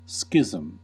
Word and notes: schism: American English

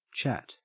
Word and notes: chat: Australian English

